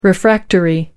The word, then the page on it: refractory: American English